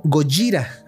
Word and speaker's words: Gojira: En su debut en los cines en Japón en 1954 de la primera película, se llamó Gojira, así lo pronuncian,